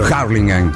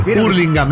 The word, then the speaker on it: Hurlingham: Harlingham. – Claro, Temperley. Hurlingham, no es Harlingham.